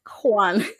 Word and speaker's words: Hwan: Hwan. – Like Julio? Hwan? Like Hwan Julio? – Like Hwan, like H-W-A-N. – Oh, okay. – Maybe I shouldn't have put an emphasis on the H.